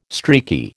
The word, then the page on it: streaky: American English